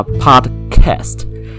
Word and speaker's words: podcast: 好，我就觉得说你是英国人，那我学的是美式英文，那我就要硬要讲 podcast，怎么样？然后他就说：“ 好，你要讲这样也可以，但是请你发音发完整，你要发 podcast。” 它的那个低的音要发出来，你不能没有发，你不能把低的音吃掉。